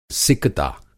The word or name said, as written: सिकता